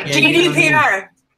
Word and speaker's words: GDPR: Or the same thing that happened with, you know, or similar thing that happened with the GDRP to a lesser degree. – GDPR. But this shit can never say that right. Yeah, GDPR.